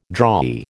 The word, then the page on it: drawee: American English